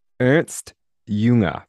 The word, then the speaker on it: Ernst Jünger: Ernst Jünger.